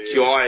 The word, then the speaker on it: Jhye: Jai, it's got like a H in it. – Yeah, J-H-Y-E. – Screaming, Jai! He's a fuckwit. – J-H-Y-E is how it's spelled.